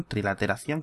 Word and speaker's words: trilateración: Eh, y luego con la trilaterización, trilateración, ¿cómo se dice? Trilateración, sí.